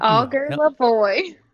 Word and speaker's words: Augerlavoie: Help Augerlavoie.